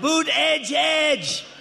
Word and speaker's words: Buttigieg: They're saying how to say his name, Boot Edge Edge. That's not a star. Boot edge edge. You say boot, and then add edge edge. Boot edge edge.